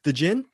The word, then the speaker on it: Djinn: Is that correct? Am I pronouncing it right, Bruce Perky? The Djinn? – Yes.